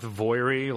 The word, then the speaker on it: Voirrey: V-O-I-R-R-E-Y. – That sounds as good a pronunciation as any. – Yeah, I'm going to go with Voiri, like Moira.